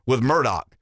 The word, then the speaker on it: Murdaugh: I think the Murdaugh case, depending on where you're from and how you pronounce it, is spelled Murdaugh. I've heard it pronounced Murdaugh, and I've heard it pronounced Murdaugh. I think they pronounce it Murdaugh, so that's, you know, we'll go with Murdaugh.